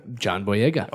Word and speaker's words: John Boyega: John Boyega.